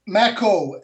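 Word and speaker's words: McCaw: We are talking tonight to the amazing Dr. Henry McCow. Welcome to the People Speak show, Mr. McCow, sir. – I'm glad to be here, and it's Macko. It rhymes with tobacco.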